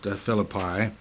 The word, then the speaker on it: Philippi: From there, they sailed from Philippi, or I've heard it pronounced Philippi, to Troas, which was a port on the Aegean Sea.